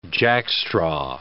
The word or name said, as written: jackstraw